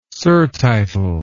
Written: surtitle

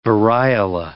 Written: variola

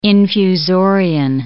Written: infusorian

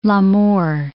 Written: L'Amour

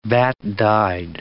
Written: vat-dyed

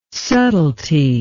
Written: subtilty

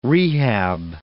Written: rehab

